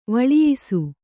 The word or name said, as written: ವಳಿಯಿಸು